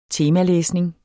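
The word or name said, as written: temalæsning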